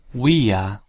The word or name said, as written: via